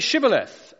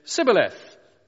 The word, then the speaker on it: shibboleth: When he said no, verse 6, they said to him, "Then say Shibboleth." And he said, "Sibboleth," for he couldn't pronounce it right.